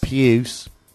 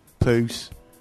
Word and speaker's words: puce: Pronounced puce, yeah. – Not poose.